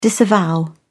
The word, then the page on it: disavow: British English